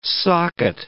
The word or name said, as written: socket